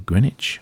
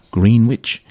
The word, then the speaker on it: Greenwich: We started off with Nancy Sinatra and Lee Hazlewood and the Greenwich, or as their pronunciation would have it, Greenwich Village folk song Salesman.